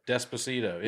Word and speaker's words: Despacito: I don't even know how to say it. – Despacito. – Is that right? – Yeah.